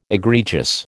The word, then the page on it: egregious: American English